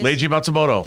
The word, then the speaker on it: Leiji Matsumoto: Leiji Matsumoto.